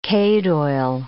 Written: cade oil

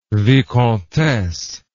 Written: vicomtesse

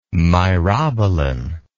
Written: myrobalan

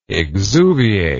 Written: exuviate

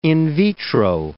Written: in vitro

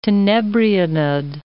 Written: tenebrionid

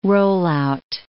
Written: rollout

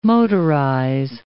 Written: motorize

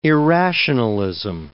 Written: irrationalism

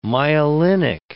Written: myelinic

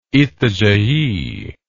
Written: Itajai

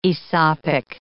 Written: Aesopic

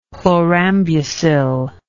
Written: chlorambucil

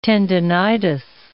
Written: tendinitis

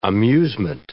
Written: amusement

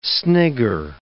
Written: snigger

